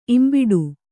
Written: ಇಂಬಿಡು

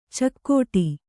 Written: ಚೌಗಡ

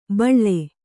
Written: ಬಳಿ

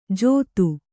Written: ಜೊತ್ತು

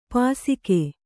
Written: ಪಾಸಿಕೆ

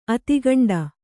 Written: ಆದಿಕಾಂಡ

